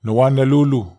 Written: nowanalulu

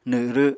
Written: nw7rw7

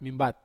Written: mambat

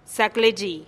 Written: zaklexi

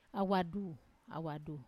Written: awadu

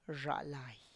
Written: rzhalay

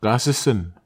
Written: kasesen

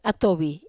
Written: atovi

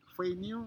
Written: feinyu

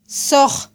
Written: soj

